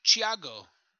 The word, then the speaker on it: Thiago: It's pronounced Thiago. Thiago.